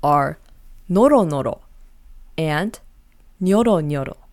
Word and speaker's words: nyoronyoro: Now the second word is nyoronyoro. Note how there is a Y between the N and the O. Now this one is a little hard to pronounce, so let's do it again. Nyoro nyoro.